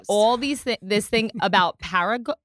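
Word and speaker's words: ParaGard: Today, we are going to do a live cleanse of Paramore. – No, Paraguard. Okay, I've been teaching her how to say this word. It's Paraguard. I mean, maybe I'm saying it wrong, but it's Paraguard.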